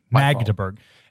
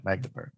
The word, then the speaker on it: Magdeburg: I thought there was a D. – Yeah. Magdeburg. Anyway, Magdeburg. – That's my note on pronunciation.